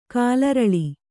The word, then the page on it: ಕಾಲರಳಿ: kālaraḷi